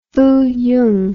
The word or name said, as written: fuyung